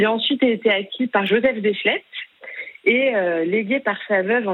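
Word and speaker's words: Joseph Déchelette: Venons-en maintenant, justement, alors je vais le prononcer comme vous le prononcez, vous. Venons-en à Joseph Deschlettes, qui était cet homme qui avait un lien très fort avec l'archéologie française, me semble-t-il.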